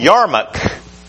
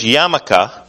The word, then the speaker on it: yarmulke: Then this is called the Yarmulke, and the most commonly recognized piece of Jewish garb is actually one with the least religious significance.